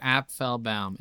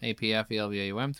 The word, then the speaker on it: Applebaum: Applebaum. Applebaum. Three syllables. Applebaum.